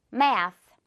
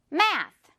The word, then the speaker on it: math: 이번엔 TH가 마지막에 붙어 있지만 상관없어요. 역시 혀 내밀으세요, 여러분. Math. Math.